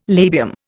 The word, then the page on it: labium: Indian